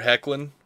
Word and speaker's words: Hoechlin: And since they're already doing it, kind of, in One Direction, they have Tyler Hoechlin, which pronunciation provided by Scott of the Suicide Squad cast. Thank you. I didn't know how to say that shit.